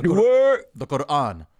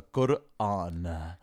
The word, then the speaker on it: Quran: The Quran. That's how you pronounce it, by the way. – The Quran? – The Quran.